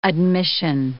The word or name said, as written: admission